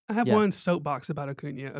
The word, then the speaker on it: Acuña: Come on, it's Acuña. – Acuña. – Just pretend like it's NYA at the end.